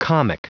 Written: comic

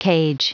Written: cage

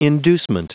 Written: inducement

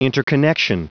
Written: interconnection

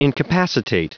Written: incapacitate

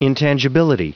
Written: intangibility

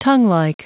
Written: tonguelike

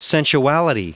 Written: sensuality

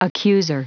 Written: accuser

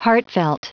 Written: heartfelt

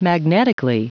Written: magnetically

